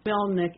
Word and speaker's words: Melnyk: And is spelled A-N-D-R-I-Y, Andry, or Andry, Melnik, M-E-L-N-Y-K.